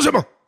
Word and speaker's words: Tsushima: I recently bought this game for PlayStation called Ghosts of Tsushima, and I, I was pronouncing it Ghosts of Tsushima, but then I was going, wait a minute, that no, this, you got to Japanese it. Ghosts of Tsushima, Tsushima.